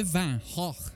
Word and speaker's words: Van Gogh: Think of it as moving from artists such as Manet to Van Gogh, that's how you pronounce it, look it up.